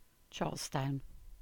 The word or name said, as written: Charlestown